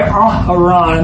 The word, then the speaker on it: Aharon: And we say Aharon, and that's the correct pronunciation. That's why there's two A's instead of just one A. Aaron is one A.